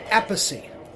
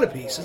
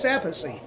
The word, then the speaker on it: Appice: Dio refused to mispronounce it. So he would say Appice, which is the way it should have been pronounced. – Mm-hmm. – And he refused to because it's a strong Italian name. He's like, no, I'm not going to call it Appice. It's not Appice. It's Appice.